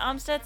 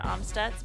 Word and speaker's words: Amstutz: I'm Sandra Omstutz, and you can find me on all social platforms at Sandra Omstutz.